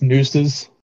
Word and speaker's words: Nxxses: It's nooses.